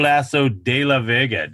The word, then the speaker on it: Lasso de la Vega: My fellow host Troy Goldsby got pulled away in the last couple minutes, so it's just me and this special guest Ernesto Lasso de la Vega. Did I say it right? – Yes, very good. Yeah. You got an A.